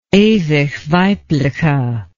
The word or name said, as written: ewigweibliche